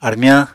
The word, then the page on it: armyañ: Apatani